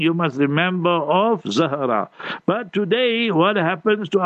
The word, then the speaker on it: Zahra: So you must say Zahra, Z-A-H-R-A-A. So then it will be like Fatima Zahra. So Zahra, Z-A-H-R-A-A.